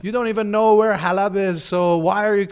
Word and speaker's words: Halab: So, for example, there's a city in Syria called Aleppo, mm-hmm. which is uh, Farsi for Halab. Yeah. And I said Aleppo.